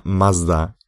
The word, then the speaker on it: Mazda: A vyšla z toho teda Mazda a já hovorím, že jako chápem, že máte nějakou svou výslovnost, ale nehnevaj se na mě, Mazda je Mazda, hej.